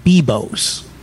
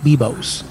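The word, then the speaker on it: Biblos: I think it's pronounced Bibos. In other words, it has a silent L. It's B-I-B-L-O-S. But I believe it's pronounced Bibos.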